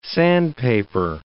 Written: sandpaper

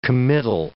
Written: committal

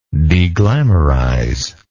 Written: deglamourization

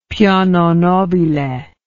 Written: piano nobile

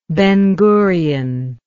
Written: Ben-Gurion

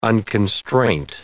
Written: unconstraint

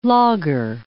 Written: lager

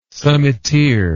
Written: summiteer

